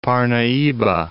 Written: Parnahyba

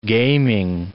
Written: gaming

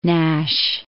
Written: Nashe

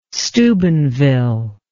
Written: Steubenville

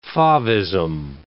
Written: favism

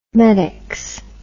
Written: medex

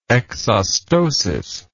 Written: exostotic

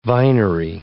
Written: vinery

